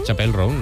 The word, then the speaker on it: Chappell Roan: Chappell Roan, ¿no? – Ella misma nos explica cómo se pronuncia.